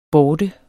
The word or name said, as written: borte